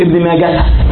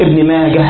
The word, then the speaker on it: ابن ماجه: وهاؤه ساكنة وصلا ووقفا. أهو تجد في بعض كتب ابن ماجه، طبعا إذا كان في ضرورة شعرية مثلا، لكن في هذه أسماء عجمية أصحابها ينطقونها هكذا، كل ابن ماجه ابن منده ابن وارة، واضح هم كده هم عندهم الوقوف على هذه الحروف، فدي لغتهم ودي هذه أسماءهم، فنأخذ أسماءهم كما ينطقونها.